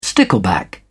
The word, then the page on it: stickleback: British English